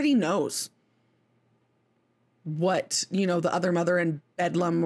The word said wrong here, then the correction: Beldam: or whatever you want to call it, Beldam, whatever, it's not Bedlam, it's Beldam.